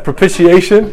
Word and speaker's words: propitiation: How do you say that? Propitiation? – Yeah.